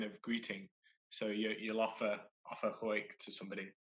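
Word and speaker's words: quaich: It's called a wake.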